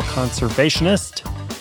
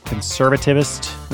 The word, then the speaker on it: conservationist: He is a dedicated environmental conservationist. I almost said that conservativist. That's not the right way to say it. Conservationist. I got it right.